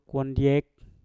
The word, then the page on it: wandyek: mpur